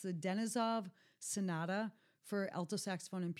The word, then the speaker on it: Denisov: Because I had a friend that did his Fulbright in Moscow, and he was very insistent that I pronounce it Edison Denisov.